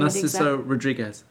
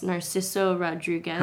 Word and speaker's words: Narciso Rodriguez: I have, it's called Her by Narcisse Rodrigo, if that's how you say it. – Narciso Rodriguez. – Yes, Narciso Rodriguez.